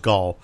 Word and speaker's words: Gal: Gull, ball, gall, so it's gall. I have a Russian friend who said that, who's Jewish, and said that it's gall, like rhyming with ball.